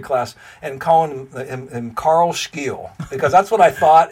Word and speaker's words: Scheele: So if I told you, his name is spelled S-C-H-E-E-L-E. what would you think without like, me saying that he's Swedish and his name is Scheele? – Maybe Scheele?